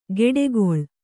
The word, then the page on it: ಗೆಡೆಗೊಳ್: geḍegoḷ